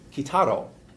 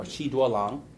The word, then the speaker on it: Kitaro: The earlier and possibly the first musician to commercially assume the Silk Road mantle was Kitaro, or Qi Duolang. Some of you may know him by his Chinese pronunciation of his name.